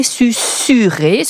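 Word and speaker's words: susurrer: En effet, tous les dictionnaires préconisent bien de prononcer Susuré, ce qui, effectivement, s'écrit Susuré. Susuré vient du latin susurrare, un S, deux R, comme Susuré, qui veut dire bourdonner pour une abeille. Alors, on continue de le prononcer comme un latin, en fait.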